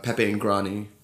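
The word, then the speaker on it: Pepe in Grani: And you talk about Franco Pepe and his restaurant, Pepe and Grani. And I apologize for my Italian. – You totally nailed it. That pronunciation was flawless.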